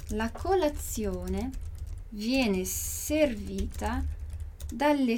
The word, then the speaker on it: dalle: Então, esse das, as, em italiano, sempre vai ser dalle, alle. Dalle, alle. Então, 2 L. Presta atenção na pronúncia dos 2 L's.